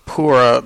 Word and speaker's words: Pura: We are currently at Yoga Pora, pronounced Pura but spelled P-U-R-A, and he also has what is called the Better Box and the Bootstrap.